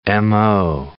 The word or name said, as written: mo